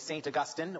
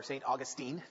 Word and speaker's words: Augustine: It's written by a man named Aurelius Augustinus, or we might know him today commonly as Saint Augustine or Saint Augustine, depending on your pronunciation.